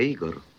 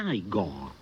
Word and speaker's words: Igor: Tu devi essere Igor. – No, si pronuncia Igor.